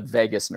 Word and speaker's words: vagus: And there's another part of our of our brain and our body that's called the vagus nerve. Okay, spelled V-A-G-U-S, um, but pronounced like Las Vegas.